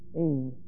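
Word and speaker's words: ēn: Ein. E-N, long E. Now this verb is the imperfect active indicative of eimi. E-I-M-I.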